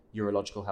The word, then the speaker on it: urological: And I don't know if a lot of guys actually kind of appreciate what urological health is. Am I pronouncing it right? – Absolutely.